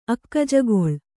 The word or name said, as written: ಅಕ್ಕಜಗೊಳ್